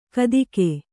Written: ಕಾತಿಕೆ

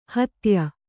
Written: ಹೆತ್ತಯ್ಯ

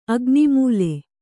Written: ಅಗ್ನಿಮೂಲೆ

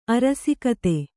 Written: ಅರಸುಗಾದೆ

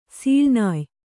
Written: ಸೀಳ್ನಾಯ್

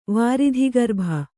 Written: ವಾರಿಧಿಗರ್ಭ

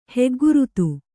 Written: ಹೆಗ್ಗುರುತು

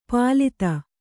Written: ಪಾಲಿತ

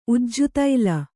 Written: ಉಜ್ಜುತೈಲ

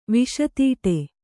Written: ವಿಷತೀಟೆ

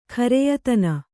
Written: ಖರೆಯತನ

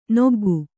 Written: ನೊಗ್ಗು

